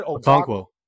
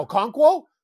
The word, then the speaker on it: Okonkwo: Okonkwo. – Okonkwo? Yeah, Okonkwo.